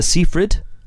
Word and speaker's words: Seyfried: Join us next week for more film-related fun, where we'll be joined by the star of Lovelace, Miss Amanda Seyfried, which is the actual pronunciation, isn't it, Ali? – I believe so.